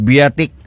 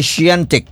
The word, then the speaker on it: Świątek: ang ilang pronunciation sa mga hostitos biatik. Pero, ang ilang pronounce kay Siantic, Siantic.